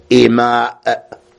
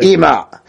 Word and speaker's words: إماء: الفصل الخامس في نكاح الإماء بكسر الهمزة مع المد، يعني لابد أن تنطق الهمزة إماء، واضح؟ جمع أمة بفتحها، بفتح شنو؟ الهمزة. هناك في الجمع تقول إماء، تكسر الهمزة الأولى. الهمزة الأولى ذي في المفرد أمة بالفتح.